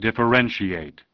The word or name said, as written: Differentiation